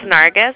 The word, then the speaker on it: Nargis: It's Nargis.